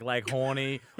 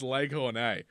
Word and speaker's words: Lake Hornay: Is it like Lake Horny or Lake Hornay? – It's actually... You're saying it wrong. It's Lake Hornay.